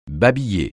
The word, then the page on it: babiller: francais